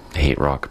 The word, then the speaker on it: HTRK: Hate Rock before that, actually spelled H-T-R-K, pronounced Hate Rock, apparently.